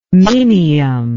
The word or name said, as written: minium